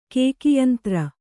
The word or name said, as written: ಕೇಕಿಯಂತ್ರ